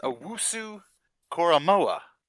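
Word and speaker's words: Owusu-Koromoa: How do you pronounce this? Owusaka. There's a hyphen in there, and you went past the hyphen. – Right. – And you put the K from the second half of the hyphen, and you moved it over to the front half of the hyphen. It's amazing that you could do that. You have a talent. – Yeah, your mind is special. – I think it's pronounced Owusu... Koromoa.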